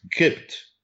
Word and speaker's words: Copt: So that the Gypt is Kipt. Gypt. – Yes. – That's how the Arabs pronounce it.